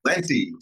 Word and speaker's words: Talenti: And then you're the guy behind the gelato Talenti. How do you pronounce it? I never know when I buy it at the store. – Talenti. Talent with an I. It's very easy to remember.